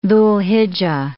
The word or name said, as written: Dhu'l-Hijja